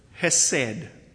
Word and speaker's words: hesed: Throughout the Old Testament, 248 times we read the word hesed. It's spelt different ways. The easiest way to spell it and pronounce it is H-E-S-E-D. Hesed.